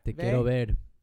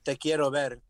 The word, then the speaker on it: Te quiero ver: Te quiero ver. – Ver? The song's name is Te quiero ver.